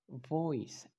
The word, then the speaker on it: voice: Voice, voice, voice, que significa voz, empieza con V. Entonces trata de pronunciarla con tus dientes superiores pegados a tu labio inferior. Voice. Ok, voice. Repeat after me. Voice. Very good. Y casi todas las palabras que empiecen con V van a ser así. Empieza ese sonido con tus dientes superiores pegados al labio inferior.